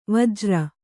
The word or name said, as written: ವಜ್ರಿ